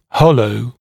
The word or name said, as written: hollow